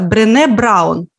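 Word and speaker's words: Brené Brown: Автор Брен, Брене пишется, Брене Браун.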